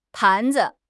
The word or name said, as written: pan2 zi5